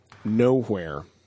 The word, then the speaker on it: Knowhere: But they were saying that there is a place in the Marvel Universe called Nowhere. It's spelled with a K, like K-N-O-W-H-E-R-E. And it's kind of an intergalactic crossroads that the Guardians of the Galaxy frequent often.